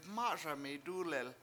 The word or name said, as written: MA7Sami dUlel